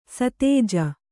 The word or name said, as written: ಸತೇಜ